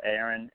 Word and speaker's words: Aaron: Yes, I do have a YouTube channel under my name, Aaron Turpeau, and that's spelled Aaron, A-A-R-O-N. Last name is Turpeau, and that's spelled T-U-R-P-E-A-U.